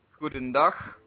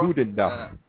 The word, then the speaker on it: goedendag: Hudendag. Or Hudendag. – Hudendag. You can also say that. – All right. And that's spelled G-O-E-D-E-N-D-A-G. Hudendag. Hudendag. And the G is pronounced H, correct? Like saying who? – I'm not really familiar with the phonetic.